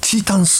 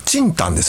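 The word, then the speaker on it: 清湯: 一つちょっとね、訂正というかですね、あの先週あの春日井の入谷のラーメンのお便りでね、チータンスープなんて言いましたけど、あの清いお湯と書いてね、あれ正確にはチンタンですね。鶏チンタンスープ、でしたね。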